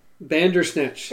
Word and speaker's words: Bandersnatch: We still have to do Boulder Dash. I know, I said it wrong. I thought about it for a second, too, and just still said it wrong. Bandersnatch.